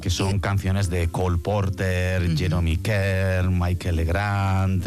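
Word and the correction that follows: Michel Legrand: o Michelle Legrand, no sé cómo se dice. – Sí, Michelle Legrand.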